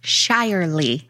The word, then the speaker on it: Shirley: It's pronounced Shirely.